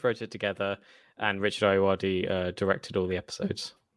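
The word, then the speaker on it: Ayoade: Ayuadi, and Matthew Holness.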